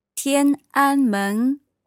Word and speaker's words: Tiananmen: Tiananmen Guang Chang.